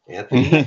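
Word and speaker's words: Michalski: So anyway, I want to thank Sylvia and Lou and Anthony Helsky. – Thank you. – And by the way, that's a silent C-H, M-I-C-H-A-L-S-K-I.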